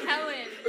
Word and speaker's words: Helen: It's Helen. H-E-L-E-N. – Helen? – Yeah, you got it. – Oh, probably couldn't understand because it's actually pronounced Helene. It's Gaelic. But whatever, it's your name, say it how you want. Even though you're saying it wrong.